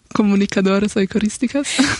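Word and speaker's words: Comunicadoras Eucarísticas: Aber für alle, die es nicht wissen, die Comunicadoras Eucharisticas, habe ich das richtig ausgesprochen? – Ja. – Ja, sagst du einmal, dass es richtig Spanisch klingt. – Comunicadoras Eucharisticas.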